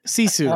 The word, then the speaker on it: Sisu: It's like a seesaw, okay. But it's a Sisu.